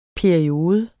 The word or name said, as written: PERIODES